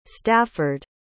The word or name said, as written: stafford